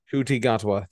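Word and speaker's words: Ncuti Gatwa: Shuti Gatwa, thank you.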